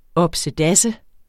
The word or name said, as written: opsedasse